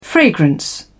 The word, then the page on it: fragrance: British English